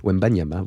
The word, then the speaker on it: Wembanyama: Victor Wembanyama, désolé, Wembanyama, voilà, ça se prononce comme ça, a récolté 28 points pour les Spurs.